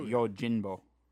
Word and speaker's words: Yojimbo: It's an M, yeah. – Well, no, but it's pronounced as an N. Like, oh, Yojimbo.